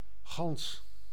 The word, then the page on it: gans: /ɣɑns/